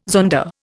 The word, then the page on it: zonda: Indian